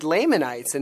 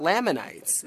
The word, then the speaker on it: Lamanites: she said Lamanites and not Lamanites. And so we've been mispronouncing it.